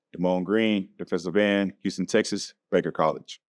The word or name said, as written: Demone Green